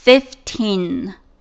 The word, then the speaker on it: fifteen: 15 的话是 five，它变成 15 的时候呢做一个小小的变形， five 变成 fif，再加上 teen 读作 fifteen 15。